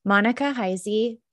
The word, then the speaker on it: Monica Heisey: On today's episode of the Professional Book Nerds podcast, I have a really special guest, Monica Heisey.